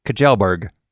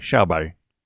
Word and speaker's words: Kjellberg: It looks to me like it should be said Kjellberg, but I know that's not correct. So we listened to a Swedish person online say his name, and it sounded like Sherby. So that's how I'm going to say it from now on.